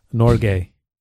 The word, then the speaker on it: Norge: Komag aplav varmen av Norge.